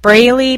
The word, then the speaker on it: Braley: If you're not listening on iTunes, or for some reason you don't see that picture, then my name is spelled JoLynn Braley, B as in boy, RALEY.